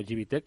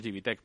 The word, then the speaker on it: GBTEC: Oye, ¿y las empresas, eh, o sea, a través de vuestro trabajo, a través de la tecnología GBT o GBTEC, que estaba yo diciendo, ¿no? – Eh, bueno, como es alemana lo llamamos Gibitec, pero en España todo el mundo lo pronuncia Gbtec.